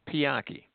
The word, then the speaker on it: Pianki: Sir, my name is Pianki. It's spelled P-I-A-N-K-I.